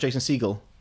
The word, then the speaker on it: Jason Segel: Because that's not how he's pronounced. He's pronounced Jason Segel. – As in, like, the bird.